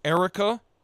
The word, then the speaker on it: Airica: Her name is, I think it's pronounced Erica. It's spelled A-I-R-I-C-A.